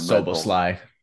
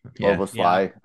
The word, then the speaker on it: Szoboszlai: We've got, I always butcher his name, the guy from Szoboszlai. – Szoboszlai.